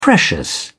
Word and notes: precious: British English

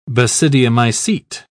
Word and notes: basidiomycete: British English

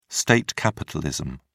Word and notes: state capitalism: British English

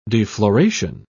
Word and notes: defloration: British English

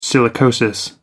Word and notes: silicosis: American English

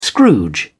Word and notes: scrooge: British English

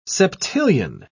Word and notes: septillion: British English

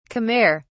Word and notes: khmer: British English

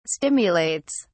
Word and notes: stimulates: British English

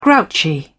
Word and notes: grouchy: British English

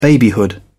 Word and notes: babyhood: British English

